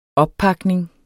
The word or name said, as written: oppakning